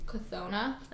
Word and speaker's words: Cthona: How do you say this word? – Cthona. Okay, I do too. – Cthona spare him. I didn't know if the C was silent.